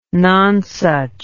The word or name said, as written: nonsuch